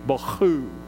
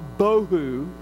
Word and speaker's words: bohu: The second word, empty, is the Hebrew word bohu, B-O-H-U, bohu, would be the way the Hebrews pronounce it, but since I'm not a Hebrew, I pronounce it bohu.